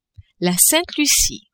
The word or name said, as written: Sainte-Lucie